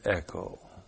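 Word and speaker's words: echo (ἔχω): Definite article, ha, T-H-E-O-S, the God. The God. And then the verb echo. E-C-H, long O.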